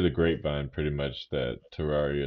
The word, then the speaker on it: Terraria: That was when we were fighting the aliens on Terraricon. – I agree. – Yeah. – Yeah, Terraria. I think you said it wrong.